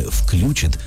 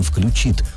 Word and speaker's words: включит: Он напомнил о том, что совсем недавно ударение «включит» стало допустимым при правильном «включит».